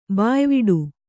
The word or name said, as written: ಬಾಯ್ವಿಡು